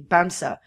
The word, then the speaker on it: Bamse: It's spelled B-A-M-S-E, Bamsa, for teddy bear.